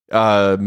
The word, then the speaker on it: Kiyosaki: Robert, and I hope I don't know, Robert Kiyosaki, is that the correct pronunciation? – Yeah, Robert Kiyosaki.